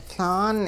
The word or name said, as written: Laan